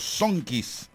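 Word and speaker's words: Zonkeys: a los Sonkeys de Tijuana. Así lo dije bien, ¿verdad? Sí, porque luego se...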